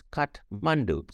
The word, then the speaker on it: Kathmandu: The way I presented headlines and the way small things like, you know, telling the newsroomers like, hey, Kathmandu is actually pronounced with an H. I know there is an AP style, but it's Kathmandu.